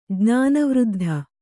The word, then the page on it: ಜ್ಞಾನವೃದ್ಧ: Kannada